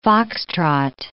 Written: Foxtrot

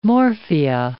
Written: morphia

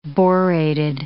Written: borated

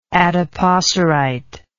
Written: adipocerite

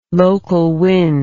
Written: local wind

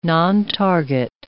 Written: nontarget

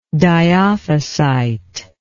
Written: dyophysite

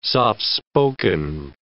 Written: soft-spoken

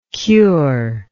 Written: curer

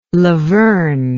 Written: lavern

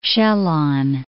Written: Sjalland

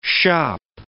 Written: shoppe

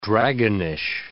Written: dragonish

